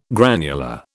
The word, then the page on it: granular: British English